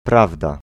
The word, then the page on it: prawda: [ˈpravda]